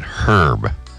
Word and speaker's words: Herb: They're pronouncing it that way. With a silent H. – That's how it's pronounced. – No, it's Peaches and Herb. – Oh, like the name. – Like the name, yes.